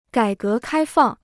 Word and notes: 改革开放: gǎi gé kāi fàng

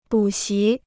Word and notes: 补习: bǔ xí

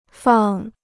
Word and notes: 放: fàng

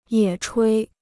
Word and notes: 野炊: yě chuī

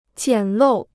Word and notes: 简陋: jiǎn lòu

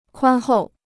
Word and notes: 宽厚: kuān hòu

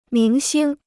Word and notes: 明星: míng xīng

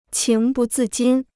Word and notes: 情不自禁: qíng bù zì jīn

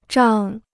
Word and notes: 胀: zhàng

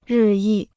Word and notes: 日益: rì yì

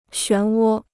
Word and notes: 旋涡: xuán wō

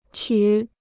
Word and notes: 曲: qǔ

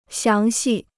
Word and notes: 详细: xiáng xì

